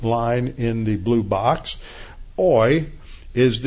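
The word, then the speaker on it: oi (οὐαί): The first word is woe, which is pronounced oi.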